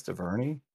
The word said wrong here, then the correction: Duvernay: Oh, it's pronounced, wait, I take it back. – It's pronounced Duvernay.